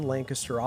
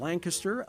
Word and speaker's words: Lancaster: Welcome back to Lancaster, and thanks for saying it right, too. – Well, that's right. One of those things you have to learn. It's not Lancaster.